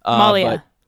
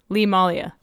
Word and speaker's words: Malia: And then I also bought Lee Malta. I hope I'm pronouncing that right. I don't think I am. – Malia. Lee Malia.